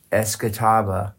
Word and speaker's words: Escatawba: I saw that, and I had to Google, how do you pronounce Escatawba? – That's it. – You know, and so I put it in here because it doesn't sound like the way it's spelled.